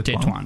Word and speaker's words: Tetuan: Viene catturata a Tetuan, in Marocco, Tituan, come si pronunciate Tituan. Tituan. Ok.